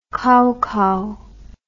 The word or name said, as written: kaukau